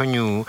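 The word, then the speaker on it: Nu: Mimochodem to, že se jmenuje Omikron, je zajímavý, protože správně se měl jmenovat ní, to bylo to řecké písmeno, které následovalo. To se v anglické literaci vyslovuje jako new a to přišlo jako, že by to vypadalo, že ten nový virus, což není.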